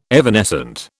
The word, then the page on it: evanescent: British English